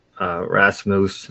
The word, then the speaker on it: Rasmus: The Rasmus, that's how you pronounce it, will be going to Buffalo.